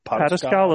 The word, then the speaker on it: Pataskala: Pataskala, Ohio.